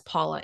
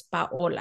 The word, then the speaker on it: Paola: I don't say my name as Paula anymore. I say it's Paola. Mm-hmm. And if people struggle with it, I kind of help them pronounce it. And I'm like, oh, it's Paola. It's like Paola. It's not Paula, Paola. It has an O in there.